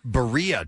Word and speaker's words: birria: Birria taco. – The birria taco? – B-I-R-R-I-A is how you spell that.